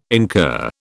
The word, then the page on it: incur: British English